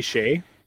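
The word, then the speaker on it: Skjei: Josh Morrissey, Noah Hanifin, Gustav Forsling, Hampus Lindholm, Brady Skye. How do you say that name? – Brady Shea.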